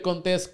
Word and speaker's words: Contés: Mi nombre es Miguel Contés, con acento en la e, este del podcast que no te tu mente.